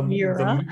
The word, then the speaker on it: Miura: It's an all bare metal body Lamborghini Moira. Moira? – Mira. – I don't know how to pronounce these words. – Moira. – Moira. – Moira. Moira. – It's a Moira. It's a Lamborghini Moira. The Miura. – Miura. I'm not Italian.